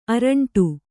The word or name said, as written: ಅರಂಟು